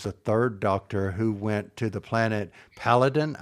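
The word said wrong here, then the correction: Peladon: I think I'm pronouncing it correctly. – Paladon.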